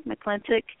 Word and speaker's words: McClintic: That's my name, Diana, D-I-A-N-A, McClintic, M-C-C-L-I-N-T-I-C.